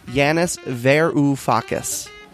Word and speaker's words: Varoufakis: Yanis V's name is pronounced Yanis Varoufakis. That's Yanis Varoufakis.